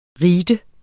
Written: ritens

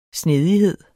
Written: snedighed

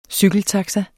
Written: cykeltaxa

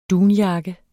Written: dunjakke

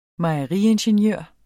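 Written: mejeriingeniør

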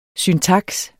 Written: syntaks

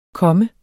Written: komme

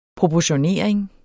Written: proportionering

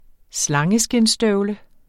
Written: slangeskindsstøvle

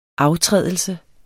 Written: aftrædelse

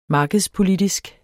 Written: markedspolitisk

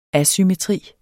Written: ASYMMETRI

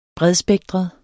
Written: bredspektret

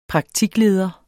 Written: praktikleder

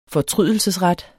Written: fortrydelsesret

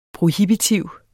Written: prohibitiv

